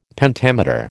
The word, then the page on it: Pentameter: American English